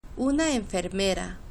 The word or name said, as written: una enfermera